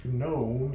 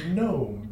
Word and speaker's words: gnome: And I'm like, no, it's not gnome, it's gnome, because we decided that the G is silent for some reason.